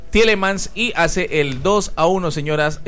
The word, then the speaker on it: Tielemans: Sí, Tielemans.